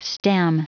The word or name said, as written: stem